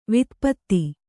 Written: ವಾತಾಪ್ತ

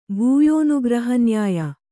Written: ಭೂಯೋನುಗ್ರಹನ್ಯಾಯ

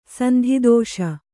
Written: ಸಂಧಿದೋಷ

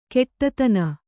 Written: ಘಟ್ಟಿತನ

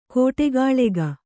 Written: ಕೂಡುಗಲಕ

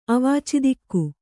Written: ಅವಾಚಿದಿಕ್ಕು

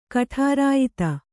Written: ಕಠಾರಾಯಿತ

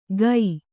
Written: ಕೈ

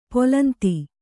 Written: ಪುಲಿಂದ

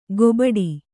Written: ಗೊಬಡಿ

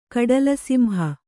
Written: ಕಡಲುಸಿಂಹ

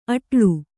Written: ಅಡಳು

